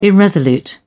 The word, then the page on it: irresolute: British English